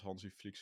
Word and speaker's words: Hansi Flick: Eh, want dit was pas Hansi Flick zijn eerste eh, toernooi, zeg ik dat goed?